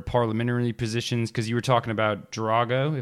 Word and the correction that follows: Draghi: Yeah, it's not Ivan Drago from the Rocky Balboa movie. it's Mario Draghi, yeah. – Draghi.